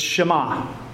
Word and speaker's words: Shema: You might wonder how to even pronounce that word. It's Shema, the great Shema.